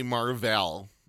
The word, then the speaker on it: Mar-Vell: And the character's name is actually Mar-Vell. But they mispronounce it and and it's a guy in the comic books. It's a man, right? – Called Captain. His name is Mar-Vell. It's an alien name. He's a Kree warrior. Kree is the alien race. – K-R-E-E.